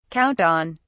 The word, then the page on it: count on: British English